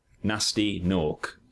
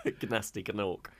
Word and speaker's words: Gnasty Gnorc: Nasty Nork. And that's with silent G's on both those words. – Gnasty Gnork.